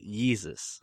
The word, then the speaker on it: Yeezus: So is it Weezus or Yeezus? – He pronounces it Yeezus.